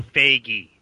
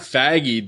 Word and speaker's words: Feige: It's pronounced F-Y-E-G-E-E. So Feige. – Feige. – Oh. Not Faggy, dude.